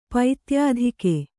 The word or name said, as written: ಪೈತ್ಯಾಧಿಕೆ